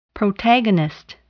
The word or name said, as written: protagonist